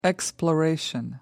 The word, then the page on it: exploration: American English